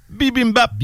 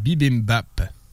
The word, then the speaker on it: bibimbap: J'ai dit peut-être trop vite. Ça s'écrit B-I-B-I-M-B-A-P. Bim bim bap. – Bim bim bap.